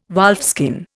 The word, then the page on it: wolfskin: Indian